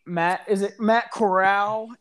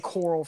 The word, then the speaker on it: Corral: And I personally think Matt Corral? Is that how you pronounce it? – Yeah, quarterback out of Ole Miss. – I've been saying Coral for the past 5 weeks.